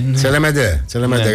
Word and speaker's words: Τσελεμέντε: Με υπέροχη θέα στον πλατή Γυαλό στη Σύφνο, στο νησί του Τσελεμέντε θα έχει φαγητό. – Τσελεμέντε, συγγνώμη. – Τσελεμέντε, γαλλικά θα το τονίζεις.